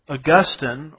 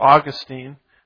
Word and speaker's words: Augustine: So the problem with Christendom, Christianity, is we fell under the spell of Augustine, or Augustine, however you want to pronounce that. Depends on which syllable gets the emphasis, as I like to say.